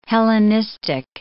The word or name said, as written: Hellenistic